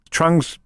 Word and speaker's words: Trung: Trung's pronounced like Hung.